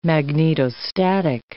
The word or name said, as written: magnetostatic